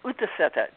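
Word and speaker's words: útiseta: The O-O-T-I-S-E-T-A-H? How do you pronounce it? – Yeah, it's Utaseta, just how you would sound, sitting out, basically, Utaseta.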